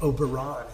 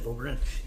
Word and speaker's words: Oberyn: And so maybe something does shift slightly, and maybe it's not the most on point nerd, you know, maybe you say the name wrong, like Oberon instead of Oberyn.